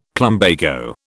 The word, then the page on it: plumbago: British English